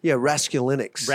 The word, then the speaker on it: Raskulinecz: Rasculinix, is that his? – Close. Like, okay, that's pretty good, man. I'm impressed. Yeah, Rasculinix.